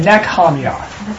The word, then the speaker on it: Nehemiah: H-E-M is where we have it in four syllables. It's only three. So N-E hyphen H-E-M, which would be Ham. And then Y-A-H would be Yah. Mm-hmm. So it's really Nehemiah is his name.